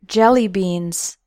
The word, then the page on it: jelly beans: American English